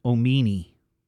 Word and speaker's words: Omeane: My, capital M-Y, apostrophe, capital K-I-S-S. My Kiss. And then the number one that she listed, a boy named Omini. O-M-E-A-N-E, pronounced Omini.